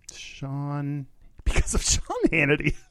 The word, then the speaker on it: Shaun: Sean Manning, S-H-A-U-N. You could probably hear that I didn't. Did I pronounce it right? Sean. – Sean.